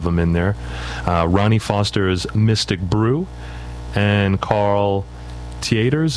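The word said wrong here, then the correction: Tjader: Is that how you pronounce his name? – I always thought it was Jader. – I says Jader. – Jader, yeah. Okay.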